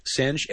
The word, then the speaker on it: Soehnge: I would say maybe Senge. – Senge, S-O-E-H-N-G-E.